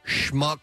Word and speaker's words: Schmuck: Police say Vernon Schmuck Jr. Yes. – Vernon Schmuck Jr. It's spelled S-C-H-M-U-C-K. That's Schmuck. – That's perfect.